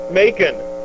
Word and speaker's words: Macon: There's, Is it pronounced Macon? – Macon.